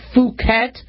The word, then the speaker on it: Phuket: And Phuket, I know you guys want to make it a short U. Let's make it a long U for now. Phuket B, right?